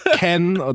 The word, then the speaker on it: Cannes: Ken spricht man das aus, Cannes geschrieben.